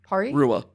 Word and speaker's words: Porirua: It's like pori, like porridge? Like pori? Pori? – Pori? – Rua. – Pori? – Yeah. – Rua. – Porirua.